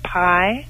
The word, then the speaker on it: Pi: Her name is Pi, like the math symbol. Just Pi.